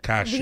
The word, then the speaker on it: cashew: Cashew. – Yes. – Ah, sí, cashew. Repeat after sí, me. Cashews, cashew. cashew. verdad, verdad, cashew. No cachuzo, María.